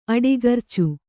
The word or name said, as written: ಅಡಿಗರ್ಚು